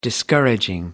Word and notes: discouraging: British English

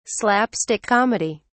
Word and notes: slapstick comedy: British English

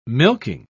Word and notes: milking: British English

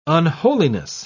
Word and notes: unholiness: British English

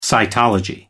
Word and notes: cytology: American English

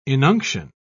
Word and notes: inunction: British English

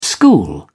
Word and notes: school: British English

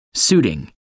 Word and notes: suiting: American English

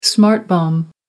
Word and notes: smart bomb: American English

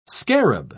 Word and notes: scarab: British English

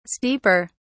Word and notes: steeper: British English

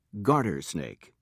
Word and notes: garter snake: British English

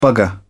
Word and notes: bugger: British English

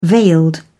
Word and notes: veiled: British English

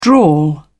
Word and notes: drawl: British English

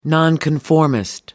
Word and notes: nonconformist: American English

